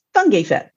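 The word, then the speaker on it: Fotheringay-Phipps: But in British English, it's pronounced Fungi Phipps.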